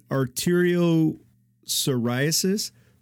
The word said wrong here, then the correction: arteriosclerosis: Most likely arteriosclerosis.